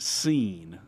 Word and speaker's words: Cine: I also, the great Claude Felton, speaking of DB signees, said that the defensive back from Texas, he pronounces it Seen. Lewis Seen. – Oh, he does. – C-I-N-E.